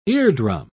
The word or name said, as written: eardrum